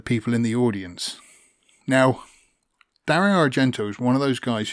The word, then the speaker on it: Dario Argento: Dario Argento.